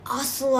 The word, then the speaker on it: ocelli: Ocelli eyes. – And the eye at the end doesn't relate to like the eyeball. It's just ocelli. That's how you pronounce that word.